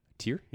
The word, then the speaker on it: TYR: Is that how you pronounce that? – Tier? Yeah.